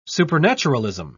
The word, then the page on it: supernaturalism: British English